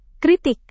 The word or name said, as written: kritik